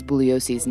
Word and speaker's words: Bugliosi: And the two attorneys constantly jab at each other, like when Spence mispronounces Bugliosi's name. – The name is pronounced Bugliosi. The G is silent.